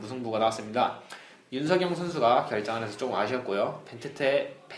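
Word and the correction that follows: Benteke: 벤테케 선수의 조금 발음 좀 그렇죠.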